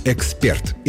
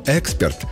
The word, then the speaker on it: эксперт: Нужно говорить «эксперт». Это единственно верный вариант, зафиксированный всеми словарями. Есть расхожее мнение, что «эксперт» с ударением на первый слог относится к профессиональному жаргону, но это не более чем мнение.